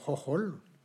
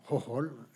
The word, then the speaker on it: Hohol: parce que ça s'écrit H-H-O-H-H-O-L. Oui. – Ben merci. C'est gentil de nous dire comment ça se prononce. – Donc, plusieurs personnes m'ont dit, ce type-là, quel nom imprononçable. Donc, c'est Hohol. Hohol.